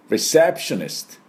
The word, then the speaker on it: receptionist: これさっきのレセプションに IST をつけて、レセプショニストって言わないよね。ストこれだけです。ダブルシーンですか。 Receptionist。こういう発音になります。